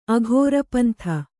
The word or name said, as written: ಅಘೋರಪಂಥ